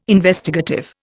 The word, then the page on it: Investigative: Indian